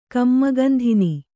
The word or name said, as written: ಕಮ್ಮಗಂಧಿನಿ